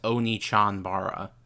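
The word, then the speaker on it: Onechanbara: It is pronounced Onichanbara.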